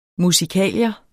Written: musikalier

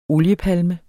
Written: OLIEPALME

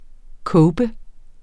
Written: cope

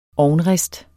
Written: ovnrist

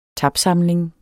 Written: tapsamling

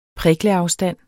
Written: prikleafstand

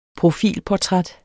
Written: profilportræt

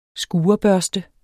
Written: skurebørste

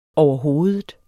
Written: overhovedet

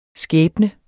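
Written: skæbne

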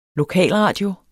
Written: LOKALRADIOER

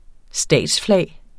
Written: statsflag

